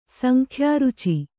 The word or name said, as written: ಸಂಖ್ಯಾರುಚಿ